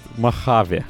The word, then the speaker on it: Mojave: Господи, как мне это слово, научусь произносить или нет? – Нет, Mojave.